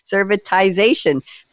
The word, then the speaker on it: servitization: I've been told to say a long I, servitization for industrial manufacturers.